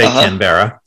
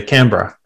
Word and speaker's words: Canberra: So it's spelled Canberra. It's spelled Canberra, but you don't say Canberra. You say Canberra.